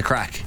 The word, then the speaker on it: craic: You usually have the crack. – The crack. – Not the. – No. You're not get. Yeah. – We spell it C-R-A-I-C.